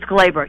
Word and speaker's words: Gleyber: It's Gleyber.